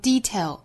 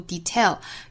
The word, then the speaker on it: detail: 这个词有两种读法，可以重音在后面也可以重音在前面，可以读成 detail 也可以读 detail，原声呢是把重音放到了后面 details 我们读成 details 也是完全 OK 的。